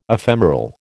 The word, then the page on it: ephemeral: American English